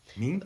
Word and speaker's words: Minh: Fun fact, in Vietnam, it's pronounced Minh. – Almost like an N, almost like a G at the end.